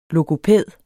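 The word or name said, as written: LOGOPÆDER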